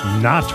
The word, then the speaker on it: Naught: It's called, is it Hot Yacht Rock or is it Yuck Not Rock? And not just spelled like N-A-U-G-T-H, but just to rhyme with yacht.